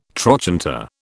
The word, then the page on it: trochanter: British English